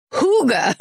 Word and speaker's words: hygge: So thanks, Trisha, except then Sarah Tunderman wrote in and said, quick correction corner, hygge is pronounced hygge.